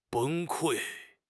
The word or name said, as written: 崩溃